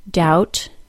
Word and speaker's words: doubt: Climb, doubt, debt, bomb. And here are the 2 words with a silent G. Campaign, design.